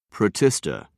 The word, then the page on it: protista: British English